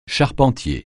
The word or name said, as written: charpentier